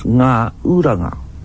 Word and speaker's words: Ngauranga: That name should be pronounced Ngauranga, the landing place of the canoes.